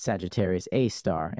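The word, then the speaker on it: Sagittarius A*: Sagittarius A, pronounced Sagittarius A star and called SGRA for short, is located about 26,000 light years from Earth.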